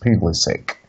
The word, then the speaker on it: Pulisic: It is actually Pulisic instead of like Pulisic. Because apparently it's the American thing and it is pronounced Pulisic.